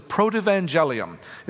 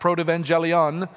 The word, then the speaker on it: Protoevangelium: I remind you, church, from time to time that Genesis 3:15 is known as the Protoevangelium. It's a theological term, sometimes pronounced Protoevangelion.